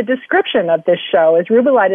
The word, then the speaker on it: Rubellite: Rubellite is not Ruby Light. So it's spelled R-U-B-E-L-L-I-T-E.